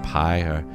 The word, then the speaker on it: Pai: That's how you say it, Pai, which translated to peace. French group.